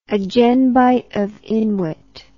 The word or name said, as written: agenbite of inwit